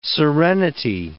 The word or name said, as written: serenity